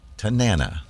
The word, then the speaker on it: Tanana: We heard Street of Dreams, Portugal the Man with Tanana, right? – Rhymes with banana.